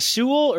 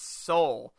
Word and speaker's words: Soule: How do you say, is it Sewell or Soule? – I think it's Soule. – Like soul, like soul food, soul.